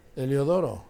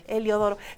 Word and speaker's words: Heliodoro: Claro que sí, hoy estamos festejando a Tomás Apóstol, Anatolio y Elodioro. Muchas felicidades. – Heliodoro. – Heliodoro, sí lo dije mal, Heliodoro.